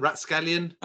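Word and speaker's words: rapscallion: How do you say it? What's the word that ends off scallion? Rough scallion? Rat scallion? – Oh, rat scallion. – Is it rat-scallion? – I wrote that word.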